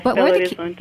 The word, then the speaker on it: Belo Horizonte: Yes, Belo Horizonte.